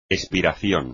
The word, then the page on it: espiración: espagnol